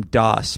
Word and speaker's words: DOS: this is from DOS, pronounced like MS-DOS.